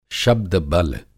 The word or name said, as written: शब्द-बल